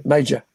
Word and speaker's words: Major: is that Aaron Moga, Major, or how do you pronounce the name? – Major. – Major, like Army Rank.